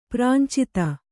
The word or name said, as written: ಪ್ರಾಂಚಿತ